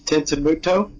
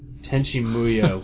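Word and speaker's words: Tenchi Muyo: The only thing I ever watched on Toonami was, Tenshimuyo. Did you guys ever watch that? – Tenchi, yeah. – Tenshimuto? – Tenshimuyo.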